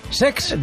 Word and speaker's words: Cesc: Lo he dicho mal, es Sex.